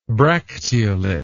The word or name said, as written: bracteolate